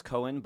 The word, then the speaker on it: Coan: My last name is pronounced Cohen, but it's C-O-A-N. – And we got the clue. – You got a clue. But people pronounce C-O-A-N wrong all the time, and I like it does.